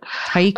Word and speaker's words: haiku: Is it Hakushu? I butchered that. The Japanese poetry. I can't remember how to pronounce it. – Haiku? – But it's just beautiful.